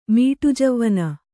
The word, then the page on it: ಮೀಟುಜವ್ವನೆ: mīṭujavvane